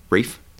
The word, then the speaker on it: Rafe: Rafi Spall is playing Eli Mills. Daniela. – It's Rafe Spalls. – Rafe? – Yeah, that's how it's pronounced.